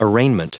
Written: arraignment

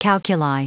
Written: calculi

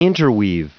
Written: interweave

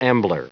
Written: ambler

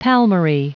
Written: palmary

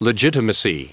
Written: legitimacy

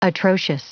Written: atrocious

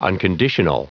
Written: unconditional

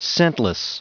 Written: scentless

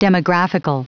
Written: demographical

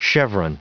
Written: cheveron

